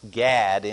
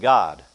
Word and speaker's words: Gad: because Gad is not pronounced Gad in Hebrew. It's pronounced God.